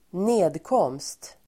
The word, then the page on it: nedkomst: ²n'e:dkåm:st